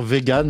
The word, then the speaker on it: Vegyn: Il faut dire vegan, même si ça s'écrit V-E-G-Y-N.